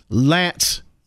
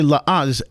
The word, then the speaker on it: L'Anse: This week I'm in Lance, Michigan. I used to say Laance, it's L'ANSE. And at first I thought it was Laance, but it's Lance, I guess the way they pronounce it.